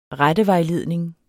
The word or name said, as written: rettevejledning